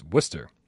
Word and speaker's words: Worcester: My son was born in Worcester, Mass, pronounced Worcester. Yeah. Traveling man 3775 Worcester, Mass. There's no R. Even though there's two in the word, we don't pronounce either one of them.